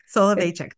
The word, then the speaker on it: Soloveychik: Soloveychik, thank you.